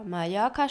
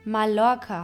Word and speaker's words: Mallorca: Dies ist das Wort Mallorca. Mallorca spricht man mit zwei Ls aus, ähnlich dem J im Deutschen und nicht mit einem L, was Mallorca heißen würde.